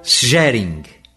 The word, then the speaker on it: Szeryng: Nós temos a pianista Ingrid Hebler e o violinista Heinrich Schering, cujo nome é um pouquinho difícil de pronunciar, mas se escreve S-Z-E-R-Y-N-G, Schering.